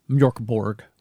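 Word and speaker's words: Mörk Borg: There's another role-playing game called Mjölkeborg, and Noel also did a soundtrack for Mjölkeborg, which I believe is also on HiMat. – It's a good pronunciation you did there.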